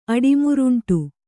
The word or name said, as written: ಅಡಿಮುರುಂಟು